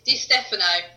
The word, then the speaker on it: Di Stefano: It's Di Stefano, Italian surname.